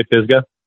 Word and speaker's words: Pisgah: Am I saying this right yet? Pisgah or Pisgah? Am I pronouncing it right? – We say Pisgah.